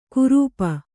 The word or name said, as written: ಕುರೂಪ